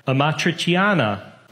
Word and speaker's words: amatriciana: Next, we have amatriciana. Did I pronounce it right? – You did a pretty good job. Yeah. That's 99% almost there.